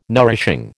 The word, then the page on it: nourishing: American English